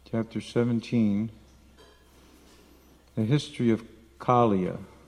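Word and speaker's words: Kāliya: It's Kaliya, ka, ka, ka. Think of a crow, ka, ka, ka. Kala means black. Kaliya, not Kaliya. There's no long I, Kaliya.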